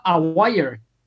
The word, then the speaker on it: Aguirre: Marc Aguirre. Claro. Y se pronunciaba a wire.